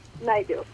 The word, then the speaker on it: Naidu: Is your last name pronounced Naidu? – Naidu.